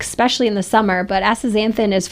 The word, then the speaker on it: Astaxanthin: But we want to talk about a product called Astaxanthin,